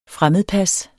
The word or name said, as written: fremmedpas